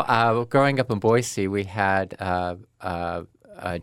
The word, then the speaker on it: Boise: Well, I grew I grew up in Boise.